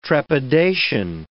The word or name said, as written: trepidation